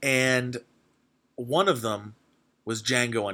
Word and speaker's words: Django: So Django, Django is the D is silent.